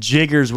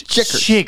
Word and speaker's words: chiggers: I said Jiggers with a J, but it's DG said it's a CH. It's Chiggers.